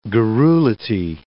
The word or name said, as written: garrulity